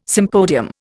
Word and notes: sympodium: Indian

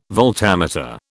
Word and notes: voltameter: British English

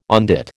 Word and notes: on dit: American English

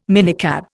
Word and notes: minicab: Indian

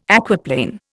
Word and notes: aquaplane: Indian

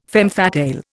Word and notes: femme fatale: Indian

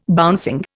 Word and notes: bouncing: Indian

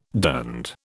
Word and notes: durned: British English

